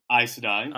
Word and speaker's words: Aes Sedai: All right, so we got the factions, you know, we've got the White Cloaks and we have the Aes Sedai. Aes Sedai.